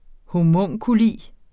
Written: homunculus

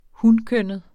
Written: hunkønnet

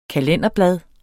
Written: kalenderblad

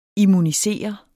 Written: immunisere

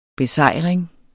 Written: besejring

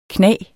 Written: knage

